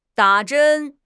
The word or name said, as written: da3 zhen1